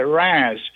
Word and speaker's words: Reims: By the way, I want to apologize for making a slight mistake on the translation of Reims, R-E-I-M-S. I said Reims. It's actually Reims. That's how they pronounce it.